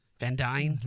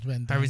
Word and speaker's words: Van Dyne: Just imagine like the chemistry that Paul Rudd has with Evangeline Lilly or Scott Lang and Hope Van Deen, Van Dyne, Van Dyne.